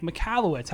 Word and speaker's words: Michalowicz: I'm here with a really special guest today, Mike Michalowicz.